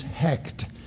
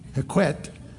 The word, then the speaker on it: Hekt: Her name was Hekt. H-E-K-T. Now, some spell it H-E-Q-E-T, Hekwet. Some spell it H-E-K-A, Heka. But the most often, and from the Egypt, is Hekt, H-E-K-T. She was a goddess again. And she is married to Khnum. You knew this, K-H-N-U-M, who is a creator god.